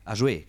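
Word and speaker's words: Ajoie: Ähm, Ajo, wie es ausgesprochen? Ajoe. Ajoué, genau. Ajoué, ähm, ein wirklicher Provinzverein, muss man sagen, hat sensationell den Pokal gewonnen, hat das Finale gewonnen gegen Davos mit 5 zu 3.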